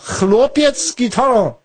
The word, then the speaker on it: Chłopiec z gitarą: Ach so, das ist so ein, ach, das ist ein A mit dem Unterstrich wird dann wie so en ausgesprochen. Sag mal nochmal. Chłopiec z Gitarą.